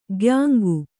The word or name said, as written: ಕಾಯಾಂಗ